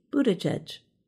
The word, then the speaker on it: Buttigieg: Transportation Secretary Pete Buttigieg, whose last name still causes some problems. During his 2020 presidential run, his campaign made shirts with the pronunciation boot edge edge to help.